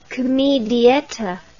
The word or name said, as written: comedietta